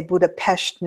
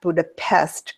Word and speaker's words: Budapest: And you'll notice I say Budapest, not Budapest, because that's how they say it over there.